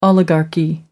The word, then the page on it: oligarchy: American English